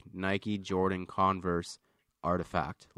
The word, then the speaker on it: RTFKT: So Nike is extending its reach into the metaverse by acquiring, so it's spelt RTFKT. It's pronounced Artifact, which is kind of a cool way to spell it.